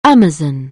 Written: amazon

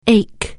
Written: ache

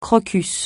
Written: crocus

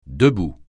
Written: debout

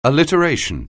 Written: alliteration

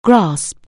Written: grasp

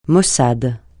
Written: maussade

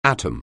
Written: atom

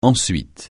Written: ensuite

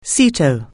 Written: SEATO

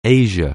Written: Asia

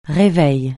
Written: réveil

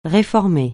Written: réformer

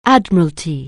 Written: Admiralty

